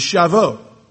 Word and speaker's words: Shavuot: The Hebrew word for Pentecost is Shavuot. S-H-A-V-U-O-T. I'll spell that again. S-H-A-V-U-O-T.